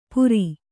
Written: ಪುರಿ

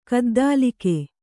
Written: ಗದ್ದಲಗೈ

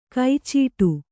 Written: ಕೈಚೀಟು

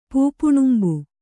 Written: ಪೂಪುಣುಂಬ

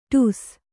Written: ಟಸ್ಸೆ

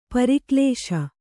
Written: ಪರಕಲಿಸು

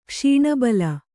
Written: ಕ್ಷೀಣಬಲ

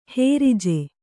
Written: ಹುರ್ಜಿ

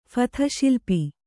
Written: ಫಥಶಿಲ್ಪಿ

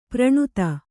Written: ಪ್ರಣುತ

